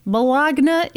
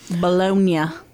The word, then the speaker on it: Bologna: Turns out he was hiding in Bologna, Italy. – No. – I don't think that's how they say it. – Bologna.